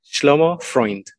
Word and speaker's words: Shlomo Freund: Did I say that correctly? Because if not, I'm going to say it again. – Shlomo Freund.